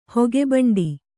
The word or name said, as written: ಹೊಗೆಭಂಡಿ